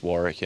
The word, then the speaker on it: Warwick: It's the second W silent, so it's just Warwick, and just don't pronounce the second W.